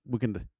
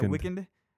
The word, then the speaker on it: Weeknd: Honestly, and they could put the E, you know, because he just puts week and then the letter N-D. – Uh-huh. – And skips the whole E for whatever reason. – It's pronounced Weeknd. – Yeah, Weeknd.